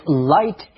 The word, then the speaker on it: light: Take the words light and bright. They both have that weird GH letter combination. Yet, those letters aren't even pronounced.